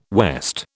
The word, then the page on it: west: British English